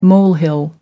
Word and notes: molehill: American English

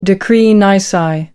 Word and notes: decree nisi: American English

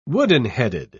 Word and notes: wooden-headed: British English

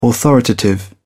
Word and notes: authoritative: British English

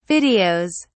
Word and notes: videos: British English

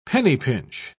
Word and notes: penny-pinch: British English

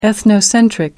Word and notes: ethnocentric: American English

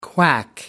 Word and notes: quack: American English

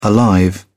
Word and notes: alive: British English

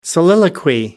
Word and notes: soliloquy: American English

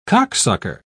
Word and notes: cocksucker: British English

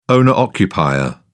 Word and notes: owner-occupier: British English

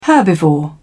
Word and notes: herbivore: British English

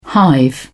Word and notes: hive: British English

